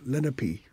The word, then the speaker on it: Lenape: and they call themselves Lenape. Is actually the pronunciation. – Lenape.